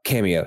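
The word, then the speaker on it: Kameo: But is honestly, it camo or cameo? – Oh, cameo. Yeah. Okay. Cameo. Again, spelled with a K.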